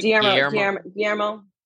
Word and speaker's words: Guillermo: Guillermo.